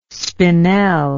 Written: spinel

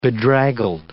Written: bedraggled

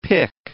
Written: pic

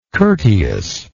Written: courteousness

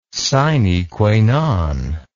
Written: sine qua non

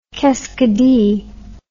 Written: keskidee

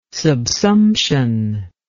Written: subsumption

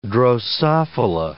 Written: drosophila

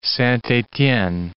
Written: Saint-etienne

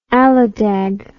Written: Aladag

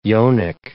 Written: yonic

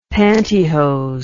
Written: pantihose

